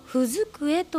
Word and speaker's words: ふづくえ: このふづくえというお店の名前の由来は、日本の昔からある読み書き机で、踏み机と書いてふづくえと読む、それが由来になっています。